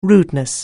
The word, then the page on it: rudeness: anglais